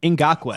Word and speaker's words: Ngakoue: Yannick Ngakoue.